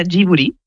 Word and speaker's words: Ghibli: En japonés se pronuncia Ghibli. Porque ellos no tienen el sonido de la L, pero es una historia bastante graciosa porque el nombre lo elige Hayao Miyazaki y lo elige por la palabra japonés.